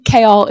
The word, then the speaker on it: Kael: I've been saying Kael is sort of pronounced like chaos with an L.